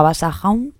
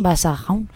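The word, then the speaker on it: Basajaun: Bueno, y ya estamos llegando hacia el final y os presento a Vasahaun, o es que yo no sé si lo digo bien, Sara, sí. Vasahaun,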